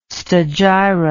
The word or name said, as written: stagira